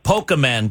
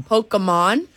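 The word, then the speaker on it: Pokémon: Investor believes that Pokemon cards, did I say it right this time? – No, you did not. – Pokemon? Investor believes that Pokemon there you go.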